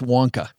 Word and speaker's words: Wonka: It's pronounced Wonka.